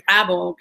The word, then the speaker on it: Bravo: In fact, my last name, believe it or not, it's hard for me to say because in Spanish, a B and a V sound exactly the same, so we can't hear the difference. So many times when I, you know, when I'm on the phone and people go, "What's your last name, Bravo?" and how do you spell that? I'm like, "Okay, B for Bob, and then R A V for Victor."